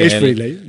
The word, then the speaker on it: Ace Frehley: Ace Frehley, sí. – Ace Frehley.